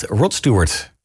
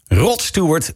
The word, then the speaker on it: Rod Stewart: De man bij wie de uitspraak heel erg afhangt van de klemtoon. Als je zegt Rod Stewart, dan klopt hij. Rod Stewart, dan klopt hij wat minder.